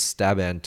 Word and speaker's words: Staban: And this guy's name is Staban Tuek, which rhymes with Raban, interestingly.